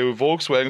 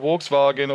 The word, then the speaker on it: Volkswagen: they say Volkswagen instead of Volkswagen.